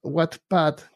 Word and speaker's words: Wattpad: Entonces, eh, lo que les quiero compartir es una página que se llama Wattpad, que se escribe W-A-T-T, Pad.